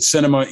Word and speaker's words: Sinema: I saw somebody, you retweeted somebody who said, it outrages me that Sinema even rhymes with cinema, right?